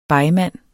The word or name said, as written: begmand